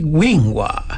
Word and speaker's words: Wingua: Usted también, un amigo de Radio Ombligo es un amigo de Wingua. – ¿Cómo se escribe? – Así, W y lingua. – Lingua. – Lingua, como lengua, pero lingua.